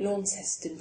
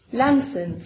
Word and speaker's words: Launceston: He lived in, um, um, well, I call it Launceston, but I think it's actually pronounced completely differently. – How's it pronounced? – Lamson.